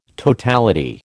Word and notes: totality: American English

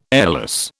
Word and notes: airless: British English

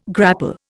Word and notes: grapple: Indian

